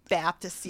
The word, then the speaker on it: Baptist: Baptist, yeah. – Baptist. – That's how they say it from the South. It's not a P, it's a B.